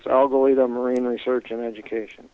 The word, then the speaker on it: Algalita: Your organization is Algalita.